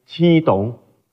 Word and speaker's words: 刺桐: 实际上斋桐的来历呢，是根据泉州当时栽种了很多的刺桐树，叫刺桐城，用刺桐的泉州话的发音七桐，翻译成为斋桐，是这样来的。